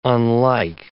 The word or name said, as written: unlike